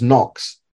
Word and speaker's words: nox: Short O sounds like the O in four. And the word I gave you was nox. Nox.